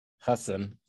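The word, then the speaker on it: Hasan: You know, so like, I have tickets to Hassan, and that's in November. – What did you just say? – Hassan. It's not Hassan. It's Hassan. I think he pronounces it.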